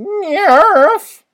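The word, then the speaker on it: NIIRYF: Also, this very podcast, can be found on Twitter at NIIRYFPod, which stands for Not If I Reboot You First, and is pronounced NIRF.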